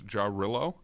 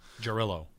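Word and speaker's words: Jarrylo: And the one that I seemed to come across the most was Jarolo. – Jarolo.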